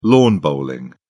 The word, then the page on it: lawn bowling: British English